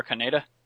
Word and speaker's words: Kaneda: There's a kid randomly standing around in a, and I know I'm going to mispronounce it, so please correct me. Kanata? – Shotaro Kaneda? – The kid from Akira.